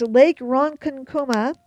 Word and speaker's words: Ronkonkoma: Yes, that's correct. Lake Ronkonkoma. And for those of you who aren't sure I'm saying that right, it's R-O-N-K-O-N-K-O-M-A, is a lake in Long Island, New York, and it is said to be very haunted.